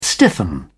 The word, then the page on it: stiffen: British English